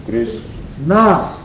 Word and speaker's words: Krishna: Krishna has two syllables also. – В слове Кришна тоже два слога. – Krishna. Krishna.